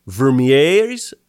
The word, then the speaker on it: Vermeire: In addition of Robert Vermeire's, how would you pronounce this? V-E-R-M-E-I-R-E. Vermeire. – There you go.